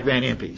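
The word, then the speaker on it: Van Impe: And there's, you know, Benny Heine and the Jack Van Wimpy and whoever it is. Did I mispronounce their names? Jack Van Impey, sorry.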